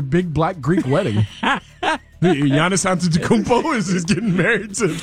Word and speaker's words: Antetokounmpo: Giannis Antetokounmpo. – Giannis Antetokounmpo. – Antetokounmpo.